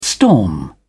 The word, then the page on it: storm: British English